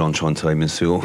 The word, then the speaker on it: Enchanté Monsieur: Yes, that last one was called Enchanté Monsieur.